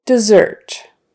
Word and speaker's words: dessert: All right, so now on to the words that are not as frequent, and those are the SSs where you pronounce the SS as a Z. There's just a few common ones. Scissors, possess, dissolve, dessert.